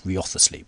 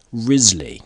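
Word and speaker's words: Wriothesley: but it's like W-R-I-O-T-H, so it's like Rioth, and then it's like E-S-L-E-Y or something, it's Riothously, but it's apparently, as with many of these aristocratic names, there is a shibboleth, a pronunciation, which only the initiated know, and I have never met any Earls of Southampton, nor any of their close friends, but I have gleaned that the correct pronunciation of this name was Risley, and English aristocratic life is full of very analogous shibboleths, where the spelling of the name bears virtually no relationship to its pronunciation.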